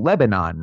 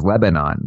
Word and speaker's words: Lebanon: That's the way you would read it is Lebanon. But the people over there, I mean, they even get on me. They say that it's got to be almost one syllable when you say Lebanon, right? – Right.